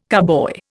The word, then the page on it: carboy: Indian